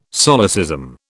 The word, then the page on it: solecism: British English